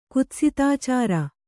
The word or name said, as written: ಕುತ್ಸಿತಾಚಾರ